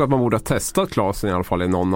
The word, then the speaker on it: Klasén: Det var då att eh, eh, Lugano-proffset eh, Linus Klasén, eller Klasen ska man uttala efternamnet, inte är med i eh, truppen.